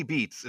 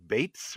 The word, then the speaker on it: Beetz: Now, Zazie Beetz is what I've been saying. I understand that it's Zazie Bates or something like that. – Really? – Like, I heard that she pronounces it very differently from the way it looks.